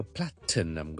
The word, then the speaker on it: platinum: 有啲人咧，尤其是英国人咧，系有时会将中间个 platinum 个停咧，就会食咗嘅。食咗咧，我嘅音节仲喺度，个 syllable 仲喺度，但系咧就会读成咧系，听住啊，照系 plat p l a t 个 t，但系我唔出嚟，我唔填出嚟，我 plat，跟住就咁条脷仲喺个 t 音位嘅时候咧，plat，跟住我读成嗯，ok plat 嗯 plat 嗯，跟住先至 num，咁就读成 platinum platinum platinum platinum，有啲人系会咁读嘅。